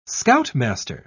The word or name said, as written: scoutmaster